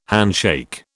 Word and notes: handshake: British English

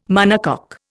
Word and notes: monocoque: Indian

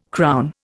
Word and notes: crown: Indian

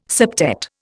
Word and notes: septet: Indian

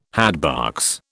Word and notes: hatbox: British English

